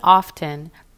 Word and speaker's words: often: And in the word often, the T is actually very light, and the TEN ending sounds like TIN, tin.